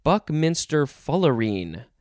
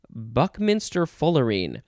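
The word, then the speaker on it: buckminsterfullerene: It looks like it is literally one word, and it is pronounced, I think, Buckminster Fullerene. Buckminster Fullerene. So we have the word buck, and then the word minster, M-I-N-S-T-E-R, and then fuller, F-U-L-L-E-R, and then een, the suffix een, E-N-E, Buckminster Fullerene.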